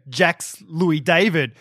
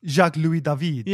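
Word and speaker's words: Jacques-Louis David: he would pronounce things very Australian. Like he'd go Jax Louis David. – Yeah. – And who was this, very famous artist. Yeah. And it's like, it's Jacques Louis David.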